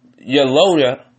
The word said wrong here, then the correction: Loyola: Loyola. That's how you pronounce it.